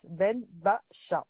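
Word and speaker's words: Benbacha: Ben Bacha.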